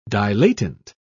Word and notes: dilatant: British English

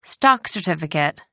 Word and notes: stock certificate: American English

